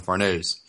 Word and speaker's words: Farnese: Or, sorry, Azil, we should talk about Azan, Serpico, and Farnese in that order. – Yeah, and you should pronounce it Farnese.